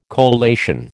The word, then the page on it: collation: American English